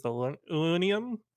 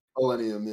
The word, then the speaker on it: Illenium: I think it's Illenium, is how you say it. – Illenium, yeah.